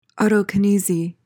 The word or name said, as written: autokinesy